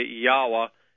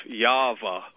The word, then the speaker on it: Yahweh: Anyway, I contend that it's Yod, He, Wah, He, and so I say Yahweh instead of Yava or Yahweh. Anyway. – Right. Yahweh, absolutely right.